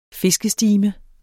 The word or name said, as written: fiskestime